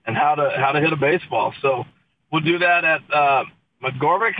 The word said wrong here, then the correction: McGavick: Am I saying that right? – McGavick.